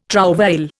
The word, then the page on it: trouvaille: Indian